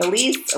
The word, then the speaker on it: Ilise: Thank you for joining me at Wide Open Spaces with Elise. Elise is spelled I-L-I-S-E.